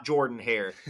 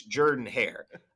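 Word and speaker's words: Jordan-Hare: make sure you pronounce it Jordan Hare Stadium. It's not Jordan Hare. It's Jordan Hare.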